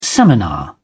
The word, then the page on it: seminar: British English